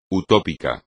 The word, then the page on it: utópico: espagnol